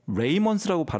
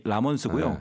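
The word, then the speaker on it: Ramones: 그래서 76년에 오늘 소개하는 이 보통 이제 Ramones라서 레이먼스라고 발음하시는 분들이 종종 계신데 본래의 이름이 라몬스고요.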